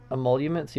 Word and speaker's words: emoluments: The first story I have listed is I don't know how to pronounce that word. Emoluments clause, Batman. Emoluments? – Emoluments, yeah.